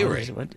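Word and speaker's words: Ehire: His first name, E-H-I-R-E. I can't pronounce it. – A-Ray. Just think A-Ray.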